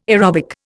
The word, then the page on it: aerobic: Indian